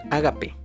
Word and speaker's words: ágape: Eh, y el último, es el ágape. Ágape con acento en la A, por si lo van a buscar, los griegos denominaron ágape al amor más puro e incondicional que existe.